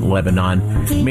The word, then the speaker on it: Lebanon: They may pronounce it Lebanon. They should pronounce it Lebanon, mainly because that's what I say.